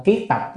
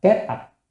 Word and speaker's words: kiết tập: Nhân A Nan kiết tập kinh văn thời đó, bởi vì lúc mà tôn giả A Nan kiết tập kinh văn, cái chữ này đọc kiết hay cát đều được nha, kết thì là kiết thì là được, nhưng mà ở trong nhà Phật thường là kiết tập chứ không đọc là kết tập đó. Cho nên là chúng ta đọc kiết giống như kiết sử vậy đó, đọc cái chữ đó là kết sử, nhưng mà thật ra là trong nhà Phật là kiết sử không ạ.